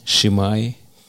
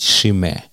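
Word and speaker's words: Chimay: И я не говорю в данном случае о коммерческом пиве, но вот это пиво, которое «шимай», я так раньше его произносил, а правильно его надо произносить «шиме».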